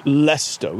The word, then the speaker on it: Leicester: And you go, it's pronounced Leicester. We don't say the E-S.